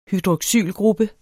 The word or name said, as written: hydroxylgruppe